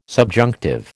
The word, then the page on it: subjunctive: American English